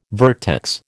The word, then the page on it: vertex: American English